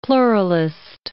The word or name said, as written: pluralist